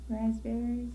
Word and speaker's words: raspberries: Raspberries? Mm-hmm. I didn't pronounce that P at all.